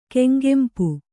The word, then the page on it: ಕೆಂಗೆಂಪು: Kannada